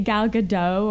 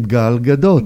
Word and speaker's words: Gal Gadot: Yeah, well, it's an Israeli name, Gal Gadot. – Gal Gadot. – Yeah, there you go.